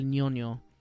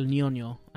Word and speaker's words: Ñoño: Yeah, so she says, so for Carlos, Lil Smokey, for CJ, El Ñoño. That's N-O-N-O, but it's got the little Ñe accent on the ends. So it's El Ñoño.